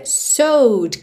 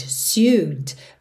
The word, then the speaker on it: sewed: Primeiro, essa pronúncia sewed, que não é intuitiva para essa escrita. S-E-W-E-D. Ok, então... Normalmente você pensaria algo como sewed, sewed, mas é sewed, sewed, ok?